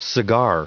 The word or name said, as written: cigar